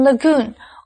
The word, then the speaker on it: lagoon: 最后我们看的是 Lagoon 这个名词, Lagoon 是指细湖或者是小而浅的淡水湖, 所以它的重音是在第二音节念成 Lagoon。